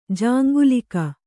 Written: ಜಾಂಗಲಿಕ

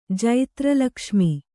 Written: ಜೈತ್ರಲಕ್ಷ್ಮಿ

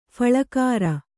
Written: ಫುಲ್ಗರ

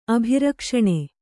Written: ಅಭಿರಕ್ಷಣೆ

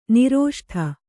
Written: ನಿರೋಷ್ಠ

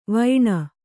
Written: ವಾಣಿ

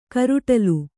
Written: ಕಾರೊಡಲು

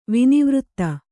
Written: ವಿನಿವೃತ್ತ

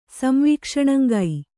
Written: ಸಂವೀಕ್ಷಣಂಗೈ

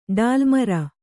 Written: ಡಾಲ್ಮರ

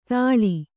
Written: ಥಾಳಿ

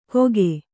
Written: ಗೋಕೆ

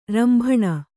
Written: ರಂಭಣ

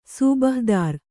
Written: ಸೂಬಹ್‍ದಾರ್

